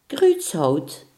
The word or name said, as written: dwarshout